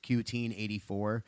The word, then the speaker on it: 1Q84: It's translated from Japanese, and the title is 1Q84, but it's supposed to be pronounced Q-teen-84.